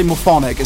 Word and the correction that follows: Morphic Resonance: It's morphic resonance.